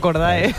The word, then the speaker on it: Cordae: No, yo digo Cordae. – Entre Cordy y Cordae, por lo que sea, me parece más americano Cordy, aunque no se diga así. Entonces, le vamos a decir Cordy, de vale.